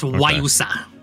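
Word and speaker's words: guayusa: It's pronounced Wayusa. G-U-I-G-U-A-Y-U-S-A. But it's pronounced Wayusa.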